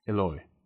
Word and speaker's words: Eloy: Eloy. – E-L-O-Y.